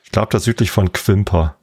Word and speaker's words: Quimper: Quimper, spricht man das aus. – Quimper, genau.